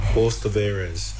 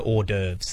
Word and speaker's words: hors d'oeuvres: Horse de Veres. It's French. Horse. That's not even how French say horse. Horse. – Horse. He's trying to get to hors d'oeuvres there. – Oh, it was hors d'oeuvres.